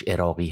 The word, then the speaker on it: عراقی: عراقی که تلفظ درستش عراقی هست